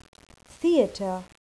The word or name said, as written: theater